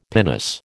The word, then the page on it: pinnace: American English